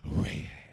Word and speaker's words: Rahab: Say it with me, Rahab. You got to say it like I said it, Rahab.